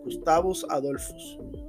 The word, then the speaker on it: Gustavus Adolphus: Y ese se llama Gustavus Adolfus Ese Adolfo es con PH. O sea, esa pronunciación de PH que tiene forma de F. Gustavus Adolphus.